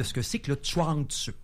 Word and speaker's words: Chuang Tzu: On prononce Chuang Tzu. Le Tzu, c'est une sorte de bourdonnement.